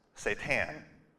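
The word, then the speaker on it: seitan: I didn't want to say Satan. That didn't sound right. And so I was like, this is literally what I typed in. And the answer came up with a little speaker. You can click it, and you can find out that it's pronounced Satan.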